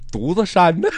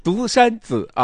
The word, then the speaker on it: 独山子: 说怎么能念成独子山呢？独山子啊，然后还有这个那拉提，就之前那个那拉提草原依然会去到啊。